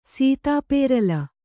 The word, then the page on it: ಸೀತಾಪೇರಲ: Kannada